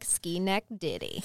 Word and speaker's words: Schenectady: And I want y'all to know that I wrote it in my notes phonetically, and it looks like ski neck ditty.